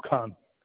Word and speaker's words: Khan: My name is Ikram Khan. – Okay, can you spell that last name? – K-H-A-N Khan. – K-H-A-N? – Yes.